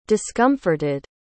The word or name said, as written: discomforted